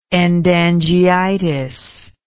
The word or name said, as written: endangeitis